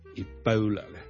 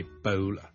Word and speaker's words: Ebola: Ladies and gentlemen, boys and girls, hi, 真故事要跟最近呢，如果大家有睇國際新聞呢，都應該會留意到 Ebola 呢個伊波拉病毒呢係某啲地方肆虐嘅。 Ebola 伊波拉病毒呢，我哋雖然廣東話我哋譯住伊波拉波啦，我哋嘅 O 呢個發音，但如果英文呢，我哋通常呢係讀 Ebola 嘅 Ebola Bow Bow，好似弓箭嘅弓嗰個 Bow， OK，咁所以呢唔係 Ebola， but Ebola， Ebola。